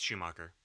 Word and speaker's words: Schumacher: I think it's interesting that even after Tim Burton's Batman, we see the Joel Schumacher films. I'm getting the name right, correct? – Yeah, it's Schumacher.